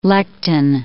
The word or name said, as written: lectin